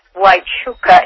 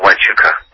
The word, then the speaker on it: Huachuca: Either China Lake or Fort, how do you guys pronounce it, Huachuca? – Fort Huachuca in Arizona. – Huachuca. Is that how you say it, Huachuca? – Mm-hmm.